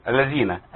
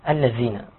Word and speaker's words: الذين: لا يحزنك الذين حرف الذال، ممكن عندي بعض الأخطاء، إن ينطق الذال زاي. – تمام. الذين. – الذين.